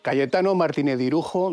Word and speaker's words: Cayetano Martínez de Irujo: Cayetano Martínez de Irujo, duque de Arjona y conde de Salvatierra.